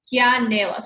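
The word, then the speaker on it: Kianela: And you know, Kiana Liani Surfboards, is it? Am I saying that right? Kiana Nela. Kiana Nela. – Kia Nela. So it's like beginning of my name, Kia, and then Nela, the beginning of.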